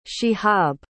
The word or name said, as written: shihab